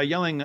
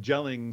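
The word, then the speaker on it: Jelling: It's yelling with a J. So it looks like gelling stones. – Oh, okay. – But J is generally pronounced in Scandinavian languages.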